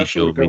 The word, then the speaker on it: Ашуркова: Э, а, Ашур, Ашуркова, это а ударение да, а простите, Ашуркова.